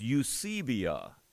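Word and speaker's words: Eusebia (εὐσέβεια): You might be interested to know that that word, when you pronounce it in the language that Paul was writing in, is Eusebia. Eusebia.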